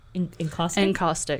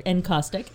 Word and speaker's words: encaustic: Encaustic. Encaustic.